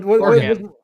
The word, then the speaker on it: Oregon: Oregon. Like you play an organ. Like your kidney is an organ. It is pronounced organ.